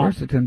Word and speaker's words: quercetin: I don't know how you pronounce it. Quercetin? – Quercetin? – Yeah. – Ah, that's it, quercetin.